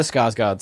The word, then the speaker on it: Skarsgård: Strafgard, I can't say his name. – Alexander. One of the Skarsgårds.